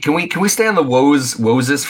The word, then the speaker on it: Woses: Woses. I kept reading that as Moses, like pronouncing it as Moses, but with a W. – Woses. Yeah.